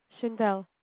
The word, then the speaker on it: Shaindel: Shindel. – Can you spell that? – S-H-A-I-N-D-E-L.